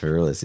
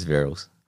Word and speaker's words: Verrills: It is not pronounced Verrills, it's Verrills.